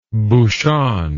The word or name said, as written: bouchon